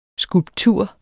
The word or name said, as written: skulptur